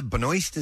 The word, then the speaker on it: Benoist: Melissa Benoist, is that how you say her name? – Yes. Okay.